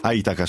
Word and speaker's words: AITACA: Allora, la dottoressa è direttrice della AITACA, che non so se si pronuncia così, l'Associazione Italiana... – AITACA, scusi, certo, è ovvio, l'accento è quello.